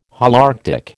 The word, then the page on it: Holarctic: American English